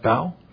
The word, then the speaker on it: bough: Joseph is a fruitful bough. Pronounce that right? Bough? Okay, thank you.